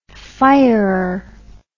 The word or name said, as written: firer